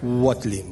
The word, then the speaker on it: Watling: Cuidado con el apellido que a mí me ha llevado meses y todavía no me entero bien. Watling, lo digo muy bien.